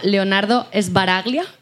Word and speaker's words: Leonardo Sbaraglia: Leonardo Sbaraglia.